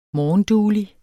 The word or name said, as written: morgenduelig